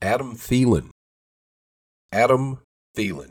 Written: Adam Thielen

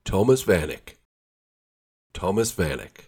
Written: Thomas Vanek

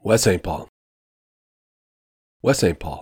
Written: West St Paul

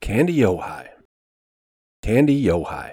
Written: Kandiyohi

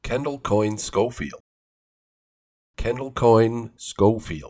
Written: Kendall Coyne Schofield

